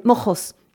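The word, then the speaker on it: Moxos: Donc, c'est avec un X, Mojos. Mais c'est, en espagnol, on peut le prononcer avec un G, comme, voilà, comme Jérôme. Hein. Mm-hmm. Mais c'est Mojos.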